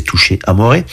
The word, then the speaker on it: Touché Amoré: Alors, ça se prononce en français, il y a des accents sur les E, donc c'est Touché Amoré.